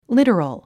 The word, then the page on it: literal: lítərəl; リトロゥ